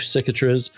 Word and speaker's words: Cicatriz: I'm going to go in this race with Chief Cicatriz, who I made sure on the pronunciation I wasn't sure. So I looked it up on Google, and they actually, because it's a Spanish word, they had pronunciation, and that's how they pronounce it.